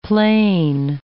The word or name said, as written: plain